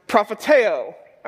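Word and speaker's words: prophetēo (προφητεύω): In the Greek, this word is pronounced prophetēo, all right?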